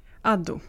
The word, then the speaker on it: Addo: Hur uttalar jag ditt efternamn? Är det A eller är det A? – Vilket Addo. Av dem? Jag har Den som börjar på A. – Addo. – Addo.